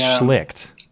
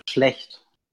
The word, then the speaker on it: Schlecht: And the German pronunciation is Schlecht, right? – No. – No. – The way you pronounce it, it would actually mean would be what you say when someone licks something. – When someone what? – When you lick something. – When you lick... Oh, Schlicht. It's pronounced Schlicht. – No, the way you pronounce it, it would be licking something.